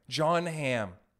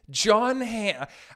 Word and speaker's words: John Hamm: I think it's going to get to the point where now if I don't see John Blam, I'm so upset about it, I can't even say his name right. John Hamm. John Hamm.